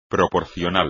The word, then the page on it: proporcional: espagnol